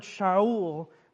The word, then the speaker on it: Shaul: Remember that word Shaul, that's how it's pronounced.